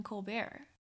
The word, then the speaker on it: Colbert: And she was featured on the show, Stephen Colbert. – Colbert. – Correct? – Yeah. – Okay. – Once again, Cressy adding syllables.